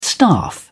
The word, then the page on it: staff: British English